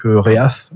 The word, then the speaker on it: Reath: Mais lorsque le vaisseau à bord duquel il voyage se retrouve éjecté de l'hyperespace lors d'un désastre galactique, Reath, parce que ça s'écrit R-E-A-T-H, donc Reath.